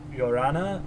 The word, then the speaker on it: Llorona: but Curse of La Llorona. I know I'm saying it wrong. I know you're supposed to say it with like a Y sound, but I'm not doing the right Y sound.